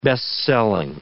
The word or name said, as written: best-selling